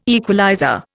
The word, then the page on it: equalizer: Indian